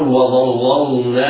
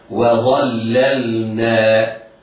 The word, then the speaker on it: وظللنا: لا تقل وظللنا عليكم، لا، انطق هكذا و شوف انظر، النون مرققة، الواو مرققة، و الظاء وظاء، اللام نعود إلى الترقيق والاستفهام، وظللنا وظللنا.